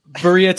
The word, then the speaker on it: birria: He said, but make sure you get some birria tacos.